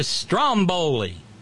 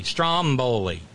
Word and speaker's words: Stromboli: God damn it, it's a Stromboli. The stress is on the first syllable. It's a volcano on an eponymous island off the coast of Italy. Stromboli. Like Strom Thurmond, right?